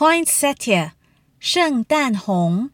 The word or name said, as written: poinsetia